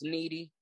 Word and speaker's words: KNDY: It's a clothing line, KNDY, and it's pronounced Needy.